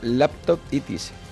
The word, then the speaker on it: laptopitis: Bueno, pues un nuevo palabra que nos ha llegado, como siempre, de tierras estadounidenses y que en inglés se pronuncia laptopitis.